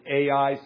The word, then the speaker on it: Ai: Some people pronounce it A-I. Some people pronounce it I. I'm going to say I.